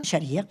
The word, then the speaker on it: Sharia: Sharia.